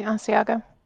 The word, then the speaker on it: Asiago: The Asiago cheese. I know I'm mispronouncing that fucking word, but I don't care. The sharpness of the cheddar. – You said it correctly, Asiago.